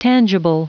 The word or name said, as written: tangible